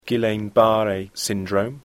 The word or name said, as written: Guillain-Barré syndrome